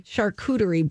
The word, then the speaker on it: charcuterie: Charcuterie board? – Charcuterie board.